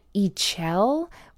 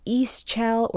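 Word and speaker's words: Ixchel: but I do know that Ichel, sometimes pronounced Ischel or Ichel, that's I X C H E L.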